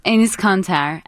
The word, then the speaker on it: Enes Kanter: Enes Kanter.